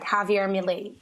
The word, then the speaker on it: Javier Milei: Isabela, and is it Javier Milei? Is that how you say his name, the new president of Argentina? – That's right. That's right. Javier Milei.